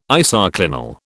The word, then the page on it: isoclinal: British English